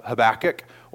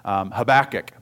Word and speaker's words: Habakkuk: Depending on what part of the country you grew up in will depend on how you pronounce it. I will probably say it both ways because I grew up in Oklahoma. So we said Habakkuk, but I think it probably should be pronounced Habakkuk, but I haven't quite broken my habit of saying it the other way.